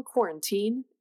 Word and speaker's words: quarantine: But yeah, Moira proposed to us that we talk a little bit about exercise during the, I prefer to pronounce quarantine with the accent on the third syllable, quarantine. era. – Oh, that is very like Ovaltine.